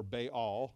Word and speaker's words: Baal: There is this famous battle in the Old Testament between the prophets of God and the prophets of Baal, or Baal, I guess is the proper pronunciation of it.